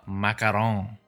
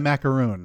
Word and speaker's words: macaron: Uh, number one for me is uh, the popularly mispronounced dessert, uh, macaron. – Oh, not a macaroon. – Not a macaroon because that is coconut based.